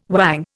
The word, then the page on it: whang: Indian